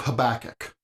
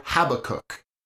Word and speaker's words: Habakkuk: In this section, we'll study the book of Habakkuk, or Habakkuk. Both pronunciations are acceptable, and I really don't wish to argue which is better.